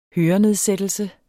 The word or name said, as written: hørenedsættelse